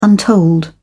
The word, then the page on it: untold: British English